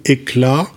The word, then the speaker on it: Hekla: Alors, si on essaye de terminer par un exemple concret, et moi je trouvais intéressant de prendre l'exemple de la tour Éclat, euh, qui, je le rappelle pour nos auditeurs, s'écrit H-E-K-L-A, euh, même si phonétiquement l'ambiguïté est autorisée peut-être conseillée,